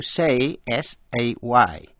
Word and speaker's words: says: Nhớ là động từ to say, S-A-Y. Khi sang ngôi thứ ba số ít, thì đọc là says. Ví dụ, he says, she says, it says.